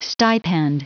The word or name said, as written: stipend